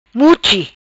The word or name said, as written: Muchi